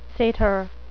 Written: satyr

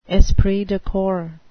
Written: esprit de corps

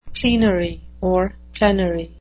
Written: plenary

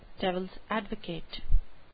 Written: devils advocate

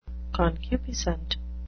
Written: concupiscent

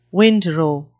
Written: windrow

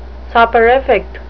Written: soporific

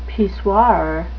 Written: pissoir

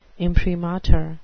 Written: imprimatur

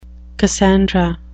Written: cassandra

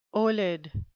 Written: olid

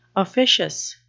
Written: officious

